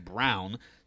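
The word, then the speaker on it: Braun: Lisa Brown, parentheses, she indicated the family's last name is pronounced Brown, said she's happy she'll have two sons playing major college basketball in the area.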